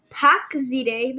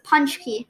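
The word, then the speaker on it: pączki: Punchki, because it was spelled really weird that I had to Google like how to pronounce this. It's spelled P-A-C-Z-K-I. And I was like, pack zide, but then I looked at Google and it says punch key.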